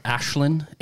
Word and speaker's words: Aisling: Ashlyn, A-I-S-L-I-N-G.